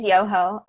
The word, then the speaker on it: Yoho: Yoho. I think it's Yoho. I watched her speech and she pronounced it as Yoho.